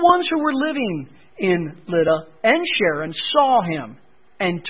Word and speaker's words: Lydda: In the Greek, it's pronounced Luda, but it's Lydda.